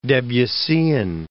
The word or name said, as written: Debussyan